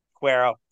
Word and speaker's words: Quero: Did I say it right? No. – Quero. – Yeah. Like there's no W sound. It's Quero.